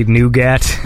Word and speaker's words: Nwgat: It's spelled N-W-G-A-T, so I just say Nougat. – Oh, you see, yeah. – It's like the stuff inside a chocolate bar.